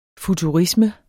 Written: FUTURISME

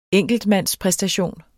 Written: enkeltmandspræstation

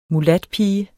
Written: mulatpige

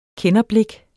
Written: kenderblik

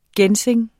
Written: ginseng

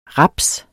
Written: raps

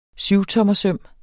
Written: syvtommersøm

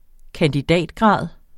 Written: kandidatgrad

